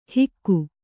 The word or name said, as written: ಹಿಕ್ಕು